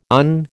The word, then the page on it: on: American English